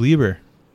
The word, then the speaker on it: Lieber: Oh, Steve Lieber. – Yeah, that. – That's cool. – That is spelled like Bieber with an L.